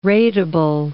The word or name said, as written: ratable